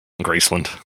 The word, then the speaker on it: Graceland: Graceland. – Yeah. – Memphis, anyway, Tennessee. – They always pronounce it like that with like no emphasis on the land.